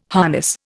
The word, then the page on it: harness: Indian